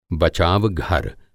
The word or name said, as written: बचावघर